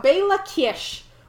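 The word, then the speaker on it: Béla Kiss: Because we're about to talk about Bela Kish, which is spelled B-E-L-A-K-I-S-S, and I'd like to thank Google Translate for pronouncing the Slovak and Hungarian names for me.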